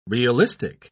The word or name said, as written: realistic